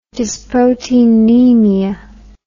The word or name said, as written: dysproteinemia